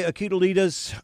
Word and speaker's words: Iguodala: And Andre Iguodala's, I know I butchered that name, dunk. Do you remember how that's pronounced? – Iguodala.